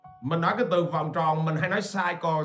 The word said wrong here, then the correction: circle: cycle cái bambi nó kêu ba ba phải phát âm là circle